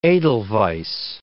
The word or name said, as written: edelweiss